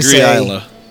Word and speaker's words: Islay: Yes, there's now, debate about the pronunciation of that because I've heard it like three different ways, but I think if you got a true Scotsman to tell you, I agree, Islay.